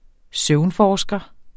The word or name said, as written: søvnforsker